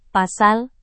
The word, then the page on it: pasal: Indonesian